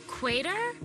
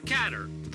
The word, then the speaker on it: Qatar: How would you say Q-A-T-A-R? – Qatar. – Quater? – Qatar. – The proper way to say it is Qatar.